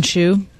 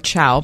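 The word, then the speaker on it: Chau: John Allen Chu. Or Chow, perhaps, is the more appropriate pronunciation.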